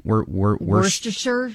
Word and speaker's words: Worcestershire: Worcestershire sauce. How do you say that? – Worcestershire sauce.